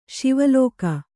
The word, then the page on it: ಸವಲಕ್ಕ: savalakka